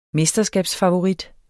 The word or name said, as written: mesterskabsfavorit